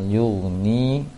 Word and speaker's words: yughni: Kalau masalah panjang pendek tidak banyak kali kesalahannya, cuma ada satu tadi kasroh yang kurang panjangnya di ayat ke-7 wala yuni Seharusnya sama panjang dengan la. Wala yughni, jelaskan lagi panjang untuk ni ketika bacanya.